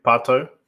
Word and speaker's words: Pato: Pato, yeah. – I know that that is my wife's fantasy football team name, Patos.